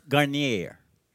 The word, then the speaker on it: Garnier: Charles Garnier, kunyari kasi marunong ako mag-pronounce na French, no? Don't pronounce the S, Garnier.